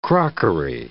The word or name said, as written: crockery